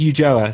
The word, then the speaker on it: Ulloa: It's Ulloa, so it's very different.